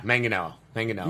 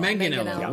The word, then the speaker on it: Manganiello: Hard G. – Just the... – Manganiello. Manganiello.